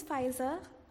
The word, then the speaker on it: Faiza: Hi, my name is Faiza. I spell my name as in F-A-I-Z-A, but back home the way we spell it is F-A-I-S-A.